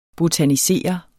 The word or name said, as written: botanisere